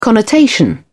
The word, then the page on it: connotation: British English